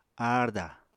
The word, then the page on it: aarda: Apatani